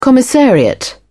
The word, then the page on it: commissariat: British English